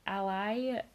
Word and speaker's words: Alai: It's spelled A-L-A-I. So Ally, the four of them together, like, defeat the rest of the group and, like, freeze them.